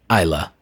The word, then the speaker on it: Islay: Someone says, is it pronounced Ila or Islay? And someone says, the residents of Ila have always said to me, welcome to Ila. And then someone says, I know a few Glaswegians who pronounce it Ily, but I think that's just their local accent dialect. The people I've spoken with say Ila.